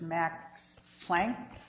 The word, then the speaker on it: Max Planck: The first patent case is University of Utah versus Max Planck.